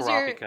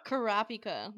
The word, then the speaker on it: Kurapika: I definitely said that name wrong. What was Kurapika. – Your... Kurapika. There you go.